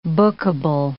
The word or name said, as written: bookable